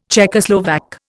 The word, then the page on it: Czechoslovak: Indian